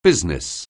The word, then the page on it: business: anglais